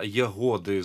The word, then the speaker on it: Ягоди: Сама назва Ягоди з наголосом на літері О, що вона означає?